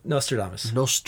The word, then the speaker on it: Nostradamus: Am I saying that right? – Nostradamus. – Nostradamus.